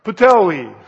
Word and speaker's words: Puteoli: The following day we reached Putello. How do I say that? What? Puteoli. Okay. Thank you.